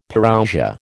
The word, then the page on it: Parousia: American English